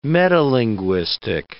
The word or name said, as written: metalinguistic